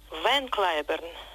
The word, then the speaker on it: Ван Клайберн: Его звали в английском произношении Харви Лейвен Клайберн. Ван Клайберн – это не искаженное голландское «ван», а уменьшительное от его второго имени Лейвен.